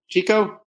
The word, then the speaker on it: Jiko: But now what I'm most excited about is I'm the co-founder and president of GECO, and we are trying to disrupt banking with a pretty unique concept. – And how do you spell that? J-I-K-O. GECO? – J-I-K-O. GECO. It's the Japanese word for self, which is at the center of the experience we're trying to create.